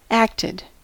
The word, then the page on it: acted: American English